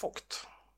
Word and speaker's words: Vogt: att det uttalas Mats Vogt. Ja, det är klart det är tyska.